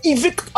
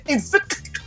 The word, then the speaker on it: invicto: Rapaz, o invicto Vasco da Gama, invicto, a pronúncia do C, invicto, invicto o Clube de Regatas Vasco da Gama ganhou mais uma.